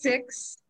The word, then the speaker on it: Sikhs: It's pronounced six, and it's spelled S-I-K-H-S.